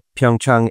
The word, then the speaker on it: Pyeongchang: I haven't been to Pyeongchang yet. And I only say it Pyeongchang because we're being told that's the proper way to say it. But I also think it's Pyeongchang.